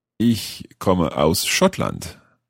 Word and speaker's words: Schottland: Schottland.